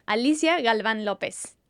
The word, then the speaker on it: Alicia Galvan Lopez: Hi, my full name, Alicia Galvan Lopez.